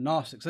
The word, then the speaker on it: Gnostics: John was writing against those Gnostics in that day. It's spelled with a G, silent G. The Gnostics.